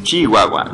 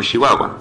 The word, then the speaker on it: Chihuahua: si están en el norte, pues no crean que pronunciamos en la mayoría del país con SH las palabras que son CH, como Chihuahua. Es Chihuahua, no Chihuahua. Entonces no se confundan.